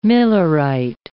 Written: millerite

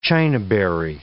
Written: chinaberry

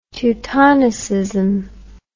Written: teutonicism

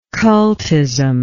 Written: cultism

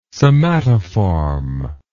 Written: somatoform

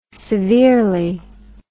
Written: severely

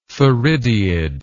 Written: theridiid

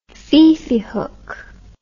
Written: fifi hook

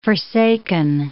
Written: forsaken

